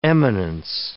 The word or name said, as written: eminence